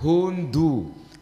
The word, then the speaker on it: huntu: Fasihnya itu dikata hundu. – Hundu. – Iya, tulisannya pasti huntu. – Iya, bacanya gitu kan? Huntu. – Bacanya per suku kata iya. – Hundu. Bukan hun.